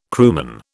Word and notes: crewman: British English

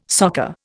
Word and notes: soccer: Indian

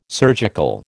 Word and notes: surgical: American English

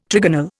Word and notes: trigonal: Indian

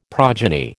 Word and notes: progeny: American English

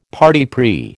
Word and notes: parti pris: American English

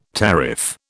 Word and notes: tariff: British English

